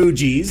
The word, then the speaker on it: Fugees: It's the Fugees.